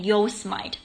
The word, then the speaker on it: Yosemite: 啊，它叫 Yosemite，Yosemite，可是你看它的拼，你会觉得它是念 Yosemite，不然就 Yosemite，它是念 Yosemite，就是蛮优胜美地国家公园。